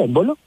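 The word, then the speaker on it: Embolo: Suiza, el jugador, en bolo, ¿cierto? ¿Se pronuncia en bolo? – Sí.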